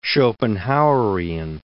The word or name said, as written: Schopenhauerian